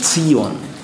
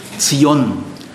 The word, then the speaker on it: Zion: Immer Ton auf der zweiten Silbe, bitte. Gell? Sag bitte nicht Zion. Zion.